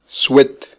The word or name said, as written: swet